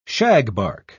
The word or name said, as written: shagbark